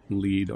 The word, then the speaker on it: LEED: Yes, I'm an accredited LEED architect. – LEED, thank you. – LEED. – Right. Now, what's entailed in being a LEED architect? – The United States Green Building Council has a program called LEED, and it's an acronym for Leadership in Energy and Environmental Design.